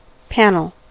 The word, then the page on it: panel: /ˈpæn.əl/